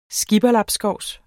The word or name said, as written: skipperlabskovs